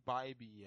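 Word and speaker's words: Bibee: It's like Bibby, I think. – I was going to say Bibby. – I was going to say Bibby, too, because I was saying I workshopped this on Ray earlier. But is like Bibby, like base on balls. That's how you pronounce it.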